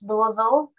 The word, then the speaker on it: Louisville: It is pronounced Louisville, which nobody except people from Louisville pronounce it that way.